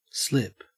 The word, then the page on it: slip: Australian English